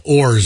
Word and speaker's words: Horry: Just call it Ors. – Is that true? Is it really silent? It's H-O-R-R-Y.